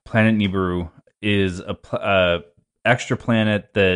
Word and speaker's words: Nibiru: Nibiru, is that how you say it? – I believe it's Nibiru.